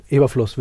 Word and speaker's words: Eberflus: Eberflus? Wie wird das ausgesprochen? – Ja. Eberflus. Wir sind ein deutscher Podcast, wir können Eberflus sagen.